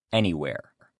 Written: anywhere

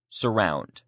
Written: surround

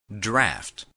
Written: draft

